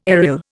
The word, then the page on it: aryl: Indian